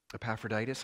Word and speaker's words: Epaphroditus: I want to congratulate Jordan for pronouncing that right. Epaphroditus, hard name to pronounce.